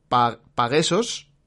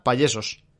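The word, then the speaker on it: pagesos: Son, está escrito con la segunda letra, es una A, serían Paguesos, y Paguesos en catalán significa pues payeses. Los payeses, pues los que cultivan el campo allí en Cataluña. Por lo tanto, la pronunciación correcta sería Pallesos.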